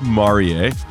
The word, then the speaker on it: Marie: Yes, it's pronounced Marie.